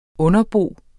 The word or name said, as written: underbo